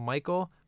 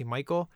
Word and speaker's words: Michael: I don't like to be called Michael, but on paper, I hate seeing Mike. It's really weird. I got to see Michael. People spell it wrong all the time. I know it's really weird, right? It's M-I-C-H-A-E-L, not M-I-C-H-E-A-L.